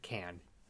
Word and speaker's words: Cannes: Damn it, Can. It was Can. – Cans. – Can. Yeah, it's okay. Can. Can. The Can Film Cans Festival. Is pronounced Can.